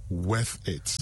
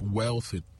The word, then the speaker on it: worth it: So I don't know how people find L in that, but look, it is W-O-R-T-H. Worth it. – Not wealth. – No. No, let's not go there. – So now I know how to say it is worth it, not wealth it.